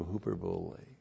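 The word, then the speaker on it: Huperbole (ὑπερβολή): What English word do we get for huperbole? Hyperbole. Good job for all you English majors. There you go. And then we also have those of you who flunked English. So, H-U-P-E-R-B-O-L long E. Huperbole.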